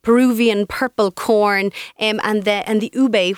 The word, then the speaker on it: ube: So it's U-B-E, pronounced ube.